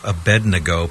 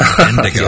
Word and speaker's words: Abednego: And by the way, can I just say, Neil, thank you for being one of the few people who actually pronounces Abednego properly instead of Abednego.